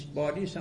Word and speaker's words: باریس: باریس همون پاریس، چون در عربی بنیس پها را به تلفظ میکنه.